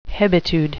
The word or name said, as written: hebetude